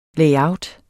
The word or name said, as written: layout